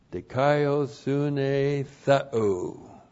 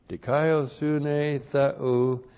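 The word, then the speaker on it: Dikaiosune theou (δικαιοσύνη θεοῦ): Dikaiosune theou. D-I-K-A-I-O-S-U-N, long E, and then T, this is theos in the genitive, T-H-E-O-U. Dikaiosune theou, the righteousness of God.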